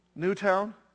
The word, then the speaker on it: Newtown: Is it Newtown or Newton? Newtown? Okay.